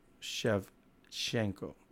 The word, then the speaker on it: Shevchenko: Valentina Shevchenko. Wait, is that how you say it? – Shevchenko, I think it is. – Shevchenko. – Shevchenko, yeah.